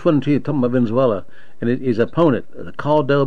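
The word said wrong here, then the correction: Guaidó: How do you pronounce his last name? Caldo? – Guaido.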